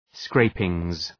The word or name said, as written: scrapings